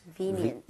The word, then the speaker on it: convenient: Convenient. – V-E에 강세를 짚고 발음하면 되겠습니다. 여러분 같이 한번 발음해 보도록 할게요.